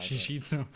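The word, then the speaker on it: jiu-jitsu: Shishizu. – Shishizu, Shishizu, oder? So, ja, das ist die brasilianische Aussprache.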